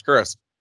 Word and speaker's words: Chris: So like, "Oh, my name's Chris." She's like, "You spell it with a K?" I was like, "No, it's a C." She's like, "CH?" I was like, "Yeah."